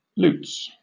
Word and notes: lutes: British English

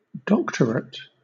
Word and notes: doctorate: British English

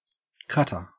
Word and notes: cutter: Australian English

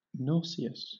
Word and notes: nauseous: British English

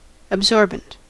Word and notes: absorbent: American English

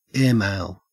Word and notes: airmail: Australian English

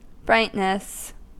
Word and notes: brightness: American English

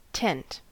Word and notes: tent: American English

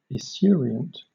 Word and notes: esurient: British English

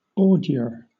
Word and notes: ordure: British English

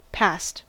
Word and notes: passed: American English

